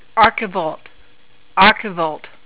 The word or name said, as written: archivolt